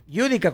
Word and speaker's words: Yudica: Yudica con acento en la U, diría mi papá.